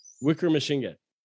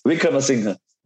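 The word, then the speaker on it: Wickremesinghe: But so what's, I think, am I, Wickremasinghe? – Wickremasinghe. – Okay, that's the president. That's the current president. – The way you said it is like Wickremachine gun.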